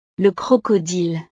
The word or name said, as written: le crocodile